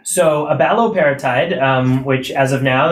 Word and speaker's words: abaloparatide: Abaloparatide. – Uh, abaloparatide.